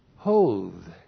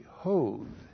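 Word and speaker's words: hoth (הוד): And then 3, the branch, the Messiah, will bear or be clothed with hoth. H, long O-D, hoth.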